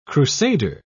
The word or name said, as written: crusader